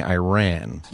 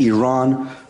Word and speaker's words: Iran: And it was a reporter from Iran criticizing an American soccer player for saying Iran. – You're pronouncing our country's name wrong. Our country is named Iran, not Iran.